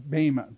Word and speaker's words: Bema: Bema. Bema. B-E-M-A, but it's pronounced, the E is pronounced A. Can you say A? A. Like the British.